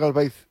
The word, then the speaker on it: Gálvez: ¿Por qué Juan Manuel Gálvez llamó a Sor Jiménez? Sí, heroína del entendimiento. – Sí, antes que nada, una corrección. Es Juan Manuel Gálvez Herrera. – Disculpa, digo la pronunciación. – Gálvez, sí.